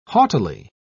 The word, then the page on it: haughtily: British English